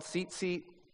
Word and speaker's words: tzitzit: They're called tzitzit, with its silent T at the front in Hebrew.